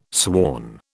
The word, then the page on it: sworn: British English